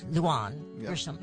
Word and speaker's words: Lujan Grisham: Lujan Grisham.